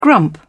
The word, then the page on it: grump: British English